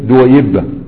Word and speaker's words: دويبة: شوف دويبة دي الياء مشددة، لكن الصواب انها ايه دويبة.